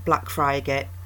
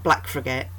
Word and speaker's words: Blackfriargate: Oh, and we do pronounce it Blackfriargate, not Blackfriargate, and I really don't know the reason why.